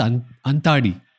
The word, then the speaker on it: Antadi: And so there's a style in Tamil poetry called Antadi. I should pronounce it right. So Anta and Adi.